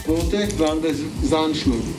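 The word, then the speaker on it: Zandschulp: Und Sandschulp, Sand, mit Z ist Sand.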